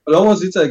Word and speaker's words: Lovosice: Vielleicht wiederholen wir das Ganze irgendwann mal im, im Rathaus von Lovosit. Wie wird es ausgesprochen? Lovosice? – Lovosice, genau, ja, ja, genau.